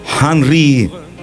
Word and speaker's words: Henri: Henri, así se pronuncia, Henri de Toulouse Lautrec, el pintor francés, de los marginales y desheredados, y del entonces flamante Moulin Rouge.